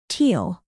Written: teal

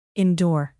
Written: endure

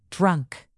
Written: drunk

